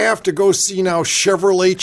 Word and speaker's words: Chevrolet: That's right, and people will love a Chevy more than they will love a, how do you pronounce this? Chevrolet? – Yeah, and that's just some old French.